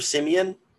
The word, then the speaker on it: Siemian: Trevor Siemian.